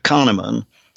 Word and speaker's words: Kahneman: It's called Thinking Fast and Slow by Daniel uh, Kahneman.